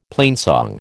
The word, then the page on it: plainsong: American English